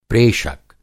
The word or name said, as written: प्रेषक